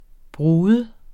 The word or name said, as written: brud